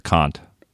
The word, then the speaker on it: Kant: And it's Kant. Like the hard C-A-H-N-T, Kant.